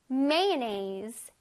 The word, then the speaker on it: mayonnaise: 마요네즈 발음이 조금 이제 특이해서 선생님이 가르쳐 주려고 갖고 나왔어요. Mayonnaise. 우리가 mayonnaise 이럴 것 같잖아. 근데 아니다. 이렇게 눈은 가만히 있고 입만 웃어봐, 얘들아. 입만 웃어봐. 힝. 이 상태로 mayonnaise 하는 거야. 시작. Mayonnaise. Mayonnaise. 입술을 이렇게 동그랗게 해서 mayonnaise가 아니라 입을 쫙 길게 한 상태로 발음해줘야 잘 발음할 수 있어요.